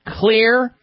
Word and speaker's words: Xlear: Clear is spelled X. L-E-A-R. The X stands for xylitol.